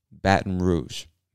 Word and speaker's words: Baton Rouge: I know it looks like Baton Rouge, and I guess you could say it that way, but most of us don't really pronounce the T in Baton, so we say Baton. Baton Rouge.